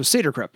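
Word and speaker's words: Saeder-Krupp: In German, usually the second vowel is pronounced. So Seder Krupp.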